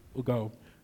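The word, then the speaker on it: euodoō: The word there is, the word prosper there is E-U-O-D-O-O. Pronounced, Yodio. Okay?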